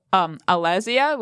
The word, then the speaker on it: Alezeia: That's by Alezia, which is spelled Alezeia. Hopefully I said that right. Yep.